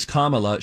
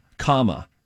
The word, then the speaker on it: Kamala: Vice President-elect Kamala Harris. Not Kamala Harris. – Right. – It is Kamala. She spells it. She says she helps people by saying C-O-M-M-A-L-A. Kamala. Kamala okay.